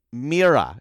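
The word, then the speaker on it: Miura: but King Kazu and Kazuyoshi Mira, if I'm pronouncing that correctly, 53. – Pretty good. That's pretty good.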